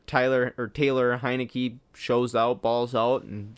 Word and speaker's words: Heinicke: I mean, Tyler Heinicke, I believe that's how you say it.